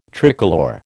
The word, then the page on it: tricolour: American English